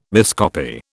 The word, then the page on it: miscopy: British English